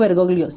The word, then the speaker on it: Bergoglio: Eh, Jorge Mario Bergoglio, sí lo dije bien.